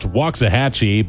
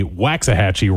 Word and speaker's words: Waxahachie: have it on good authority from people who live in the area that it is pronounced Waxahachie, but there are a lot of singers and songwriters who, for whatever reason, go the Waxahachie route.